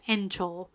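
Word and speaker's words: Encho (園長): which is pronounced Encho. And extension in Japanese is also pronounced Encho.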